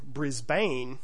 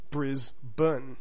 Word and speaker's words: Brisbane: Basically, the rule here is that any time an Australian city has a B and an NE at the end of its name, you can just go ahead and drop all the letters in between. So instead of Melbourne, it becomes the more accurate Melbourne. Instead of Brisbane, you have Brisbane.